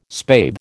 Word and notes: spathe: American English

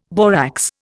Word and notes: borax: Indian

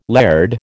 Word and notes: laird: American English

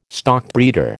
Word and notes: stockbreeder: American English

